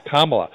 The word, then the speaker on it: Kamala: And, you know, Kamala, am I pronouncing it right now, Kamala? – You're getting closer. Kamala.